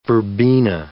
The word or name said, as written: verbena